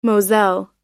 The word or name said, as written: moselle